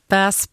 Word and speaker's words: bass: Bass Perseids. Eller, man säger tydligen bass såklart, skriver Alexius. Bass. It's all about that bass, inte bass.